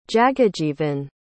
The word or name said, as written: jagajeevan